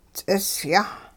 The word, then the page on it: ts'isyah: Hupa